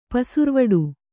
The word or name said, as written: ಪೆಸರ್ವಡೆ